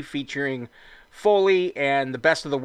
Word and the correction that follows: Folly: Did I say that right? Is it Foley? – It's Folly.